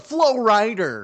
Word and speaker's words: Flo Rida: That's not how you say his name. – What did I say? Oh, yeah, I said Rider, didn't I? Yeah. – You said Flo Rida.